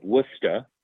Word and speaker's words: Worcester: I was wandering through the city of Worcester, as you properly pronounce it, Massachusetts. It's not Worcester. – More like Worcester. – Worcester. Yeah, Worcester. – It's Worcester. – Worcester.